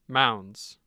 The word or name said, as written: mounds